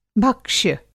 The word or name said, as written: भक्ष्य